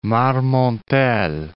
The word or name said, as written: Marmontel